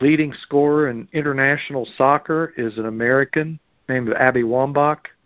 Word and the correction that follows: Wambach: It's pronounced Wambaugh.